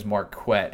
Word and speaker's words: Marquette: There was the punt that Marquette, and yes, his name is Marquette, as he pronounced before the game.